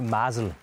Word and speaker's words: Mazel: Ja, først og fremmest så udtales det for Marcel.